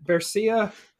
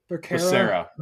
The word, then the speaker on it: Becerra: But anyways, Xavier, is it Bercia? – Becerra.